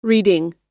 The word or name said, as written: reeding